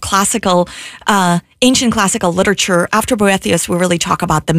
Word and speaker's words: Boethius: Hopefully I'm pronouncing that correctly. If not, you can correct me. – Yeah, Boethius. – Boethius. – Well, I've heard Boethius as well, so I think you're okay with either one.